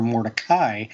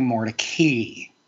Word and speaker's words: Mordecai: It may have been these circumstances that caused Moses to change the pronunciation of his last name from More-deh-kai to More-deh-kee.